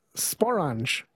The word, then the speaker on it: sporange: Dave writes, Sporange rhymes with orange.